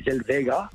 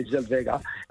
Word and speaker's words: Zellweger: You can't pronounce the W as a W. It's got to be a V, so it would be Zellweger. – Okay. – Renee Zellweger.